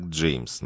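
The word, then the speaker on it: Jame: Имя произносят как Джеймс, но без «С». Джейм. Настаивает, что именно так и следует его произносить.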